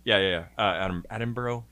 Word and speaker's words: Attenborough: Richard Attenborough's character. Attenborough. Sorry, I said that wrong.